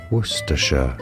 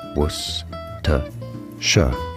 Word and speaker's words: Worcestershire: Worcestershire, stress on the first syllable. Worcestershire, yeah, only three syllable, that's odd, isn't it? Worcestershire, Worcestershire, as in Worcestershire sauce.